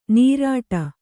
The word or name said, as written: ನೀರಾಟ